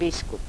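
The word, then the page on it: biskup: dialetto croato di Lukezi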